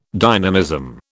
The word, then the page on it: dynamism: British English